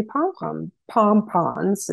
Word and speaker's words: pompons: If you just did ball dahlias, you could do those little tiny pompons is how you pronounce it.